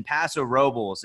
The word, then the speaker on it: Paso Robles: And yes, I pronounced that correctly, Paso Robles. A lot of people think it's Paso Robles. That included me until I actually moved to the Central Coast, and I was quickly acquainted to the fact that I was pronouncing it incorrectly, although, yes, the Spanish pronunciation, you would think it'd be Robles.